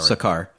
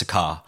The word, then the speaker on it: Sakaar: He was going to go to Scar? – Scar, sorry. – Sakaar. – Yeah. – Okay, I thought it was two syllables, my bad. – No, it's Sakaar.